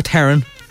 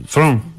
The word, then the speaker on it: Theron: In South Africa, if you are Afrikaans speaking, you would say Tron.